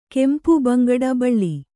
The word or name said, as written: ಕೆಂಪು ಬಂಗಡ ಬಳ್ಳಿ